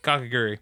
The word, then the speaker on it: Kakegurui: We're going to be talking about Kakegurui. – Yeah. – How do you say it? – Kakegurui.